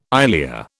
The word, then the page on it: ilia: British English